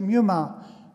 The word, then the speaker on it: pneuma: You don't pronounce the P, just in English we say pneuma.